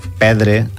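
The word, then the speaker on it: perdre: Crec que és recomanació de no pronunciar-la. Pedre, arbres, és la pronunciació més... – Pedre, correcte, en tot cas més natural.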